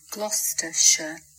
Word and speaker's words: Gloucestershire: Yeah, and it's not Worcestershire, but it's Gloucestershire. So here it is again. – Gloucestershire.